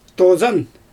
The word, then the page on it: tozan: Xyzyl